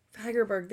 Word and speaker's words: Fagerberg: Fagerberg, there we go.